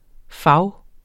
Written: fag

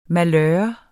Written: malør

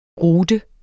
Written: rute

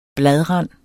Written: bladrand

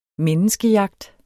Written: menneskejagt

